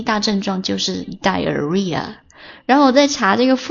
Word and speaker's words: diarrhea: 然后痢疾呢叫做 diarrhea， d-i-a-r-r-h-e-a，这个 h 的话是废物，在这个地方它不发音 diarrhea，痢疾或者腹泻。